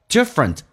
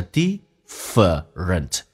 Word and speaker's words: different: Different 在美式发音当中那个 a 是没有的，它原来的英式发音叫 different，这个 a 跟 r 字母组合在一起经常被 r 所吸收，就变成 different。